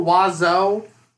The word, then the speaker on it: oiseau: It's a bird, and it's O-I-S-E-A-U-X, I believe. – Is oiseau? – Someone yes. – said one of the comparable pronunciations is just Weezer.